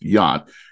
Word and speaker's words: Jagt: Now, for people looking up Ben, he does spell his last name J-A-G-T, but it's pronounced Yacht.